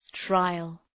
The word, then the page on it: trial: ‘traıəl